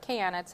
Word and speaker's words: Kayanna: I am here with Kayanna today, like banana, right? – Yeah. – It's how you say her name properly. Don't get it wrong.